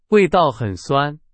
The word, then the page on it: 酸: Chinese